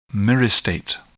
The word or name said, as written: myristate